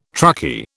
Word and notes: truckie: British English

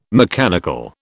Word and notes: mechanical: British English

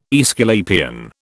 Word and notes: Aesculapian: British English

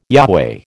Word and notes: Yahweh: American English

